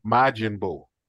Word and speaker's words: Majin Buu: Majin Buu.